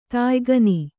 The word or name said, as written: ತಾಯ್ಗಣ